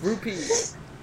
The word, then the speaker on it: rupees: I thought it's P, rupee! – It's rupees.